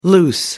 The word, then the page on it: loose: American English